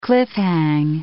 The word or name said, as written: cliff-hang